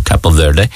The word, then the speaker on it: Cape Verde: I used to call them Cape Verde up to the World Cup, because then when I did the old Google Translate, the Google pronunciation thing, it does come up as Cape Verde or Capo Verde, whichever.